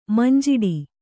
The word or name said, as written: ಮಂಜಿಟಿ